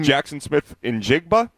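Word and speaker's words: Jaxon Smith-Njigba: Jackson Smith and Jigba.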